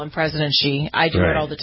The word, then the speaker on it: Xi: Is Xi, right? Isn't that how you pronounce it? – Very good. I'm very impressed.